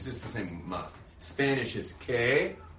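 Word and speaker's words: Ma (מה): Ma.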